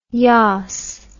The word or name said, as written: yaas